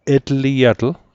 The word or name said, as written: etliatl